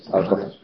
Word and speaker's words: Aristófanes: Que lo pronunciaráis distinto, pero a lo mejor lo reconocéis. ¿Cómo se decís? ¿Aristófanes? ¿Aristófanes? Aristófanes, vale.